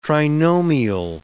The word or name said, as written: trinomial